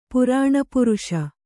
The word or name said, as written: ಪುರಾಣಪುರುಷ